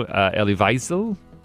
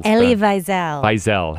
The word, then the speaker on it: Elie Wiesel: I didn't know Elie Wiesel. – Elie Wiesel.